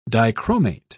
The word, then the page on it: dichromate: British English